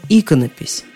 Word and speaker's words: иконопись: Икона, иконография, иконописец, иконописный, иконостас. Но иконопись.